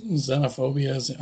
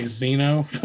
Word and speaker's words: xenophobia: Xenophobia is how you say it. – I was going to say Xeno. I don't know why, because the X.